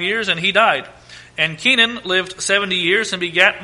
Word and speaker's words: Kenan: And Canaan is probably more properly pronounced Kenan, because it is K-E-N-A-N, K-E-N-A-N in 1 Chronicles. So instead of it being pronounced Canaan, it's probably more properly pronounced Kenan, Kenan.